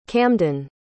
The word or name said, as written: camden